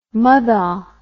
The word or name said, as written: muthah